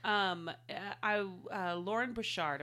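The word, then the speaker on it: Loren Bouchard: Literal children Lauren Bouchard, am I saying his name right? – Yes.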